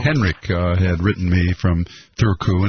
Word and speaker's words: Turku: It's not Turku, T-U-R-K-U, but it's Thurku.